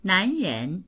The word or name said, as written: nan2ren2